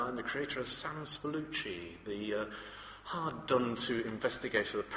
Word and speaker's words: Spallucci: I'm so glad to hear you say it out loud. I've always read it and never knew how to pronounce his name, Spallucci.